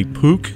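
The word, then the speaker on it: peuk: Just the pronunciation is actually Pook.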